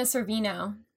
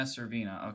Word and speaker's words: Sorvino: So this song was also used for the 2000 movie Loser, which featured Jason Biggs and Mina Saveri. Is that how you say your last name? – No, Mina Sorvino. – Mina Sorvino, okay.